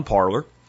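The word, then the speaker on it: Parler: I'm going to call it Parlor. That's what the guy that invented it calls it and then you parlay on Parlor.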